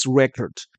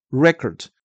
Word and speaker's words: record: 这边的记录啊，前面的那个 the number recorded, 我们大家都知道是 record, R-E-C-O-R-D, 它当成动词的记录的时候是 record, 当成名词的记录的时候，也就是后面的 from the previous record, 它是念成 record, 而不是 record。